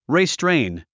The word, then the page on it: restrain: ristréin; リストレェイン